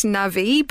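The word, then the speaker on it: navvy: I, I, think it's pronounced navi, but you know, sure.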